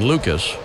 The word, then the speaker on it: Lukes: And an 0-1 count on Lucas. Spelled L-U-K-E-S. Looks like Nathan Lukes, but pronounced Lucas.